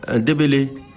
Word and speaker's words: Ndebele: Simakale Ndebele, voilà, c'est un nom sud-africain, hein, donc même s'il n'y a pas accent, on prononce comme s'il y avait accent. Diaboulo Simakale Ndebele, c'est un professeur de littérature et écrivain de fiction sud-africain, ancien président et directeur de l'Université du Cap.